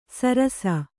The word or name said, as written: ಸಿರಸ